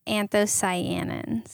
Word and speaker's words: anthocyanins: Is it cyanoids? What is it? – It's anthocyanins. – Anthocyanins. I mix different syllables.